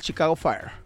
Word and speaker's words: Chicago Fire: Y el Monterrey le pegó 2-1 al Chicago Fire.